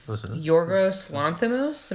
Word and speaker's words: Yorgos Lanthimos: So for best director, we have Alfonso Cuarón, Yorgos Lanthimos.